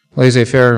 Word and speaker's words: laissez-faire: Laissez-faire rhymes with lazy-faire.